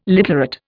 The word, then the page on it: literate: Indian